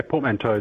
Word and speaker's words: portmanteau: Yeah, portmanteau is the correct way to say it too.